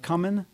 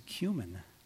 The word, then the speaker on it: cumin: I used to call it cumin, but then since we have in America eight stations showing 24-hour-a-day cooking channels, I've learned that, oh, this is cumin.